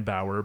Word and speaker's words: Bauer: Uh, just type in my name, Dan Bauer, B A U E R, spelled like the hockey equipment, spelled like Jack Bauer, sauerkraut, that type of thing.